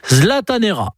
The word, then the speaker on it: zlatanera: Et si vous allez en week-end à Stockholm, n'hésitez pas à prononcer le verbe « zlataner » à l'infinitif. Ça se prononce « zlatanera ».